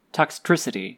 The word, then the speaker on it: Toxtricity: Up next is Geodude versus Toxicity. Toxtricity. I always get that mispronounced.